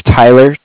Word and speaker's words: Tyler: My name's Tyler, T-Y-L-E-R, and I'm calling with Tyler.